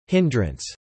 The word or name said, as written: irritability